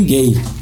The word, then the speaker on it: Gaye: É Marvin Gaye. É Marvin Gaye a pronúncia? É, a pronúncia é gay mesmo.